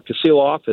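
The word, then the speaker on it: Kasilof: So the Kaseelof River, or Kaseelof as it's more appropriately pronounced, is the prime steelhead river in the Kenai Peninsula.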